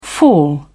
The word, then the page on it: fall away: British English